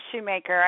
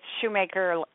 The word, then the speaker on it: Shoemaker: Yes, you are, Shoemaker. I tell everybody it's Shoemaker, like Shoemaker and the Elves.